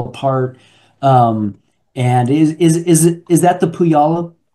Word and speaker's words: Puyallup: Well, that's the spelling, but it's pronounced backwards. It's Puyallup. – Puyallup. Thank you very much. – That's a lot of people make that, particularly people not from the area. – Particularly Californians. – Yeah, make that mispronunciation all the time because that's how it's spelled, but it's Puyallup.